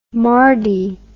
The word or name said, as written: mardy